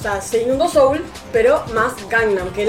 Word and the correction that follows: Seúl: Seoul es Seúl. Seúl. perdón, perdón, porque bajé la, la, la, pronunciación al argentino perdón, tenés razón.